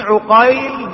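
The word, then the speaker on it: عُقيل: محمد ابن عقيل بفتح العين، ومحمد ابن عقيل بضم العين، فأولهما نيسابوري والثاني فريابي، وكلاهما مشهور وطبقتهما متقاربة، تقارب في الطبقة.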